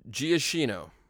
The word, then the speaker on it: Giacchino: Michael Giacchino.